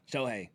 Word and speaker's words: Shohei: How do you pronounce his name? Shohei Ohtani? – Yeah, Shohei. – Shohei? – Yes, sir.